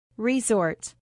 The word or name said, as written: resort